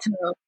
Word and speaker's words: Tove: And it's called Tove, right? Mm-hmm. I'm afraid I'm going to mispronounce it. Is that right? – Yeah. Church Called Tove.